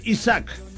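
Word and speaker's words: Isaak: Su apellido es Isaac. – Ajá. – Algunos lo pronuncian Cris Isaac.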